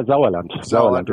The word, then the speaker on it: Sauerland: Yeah, Sauerland, right? – It's a soft S.